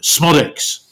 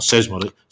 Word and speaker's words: Smodix: Yeah, but I'll get the pronunciation right. Smodix. Not Sesmodic. Smodic, sort of like mercurial, talented.